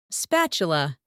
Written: spatula

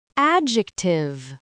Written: adjective

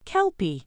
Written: kelpie

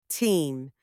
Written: team

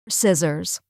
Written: scissors

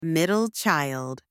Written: middle child

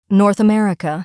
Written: North America

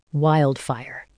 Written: wildfire

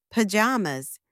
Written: pyjamas

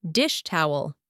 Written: dish towel

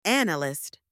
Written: analyst

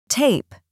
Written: tape

